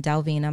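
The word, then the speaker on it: Delvena: When you hear me say Dr. Delvina, doctor is shortened, it's D-R, it's not spelled out, it's D-R, and then Delvina, my name is spelled D as in Delta, E-L, V as in Victor, E-N-A.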